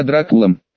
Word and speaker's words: Дракул: Мне тут недавно письмо прислали, где неизвестный пообещал мне скорое пробуждение и назвал меня Дракулом. Да-да, именно с ударением на «у».